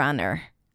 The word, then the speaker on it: honor: And then honor, even though it's spelled with an H, we pronounce it without an H.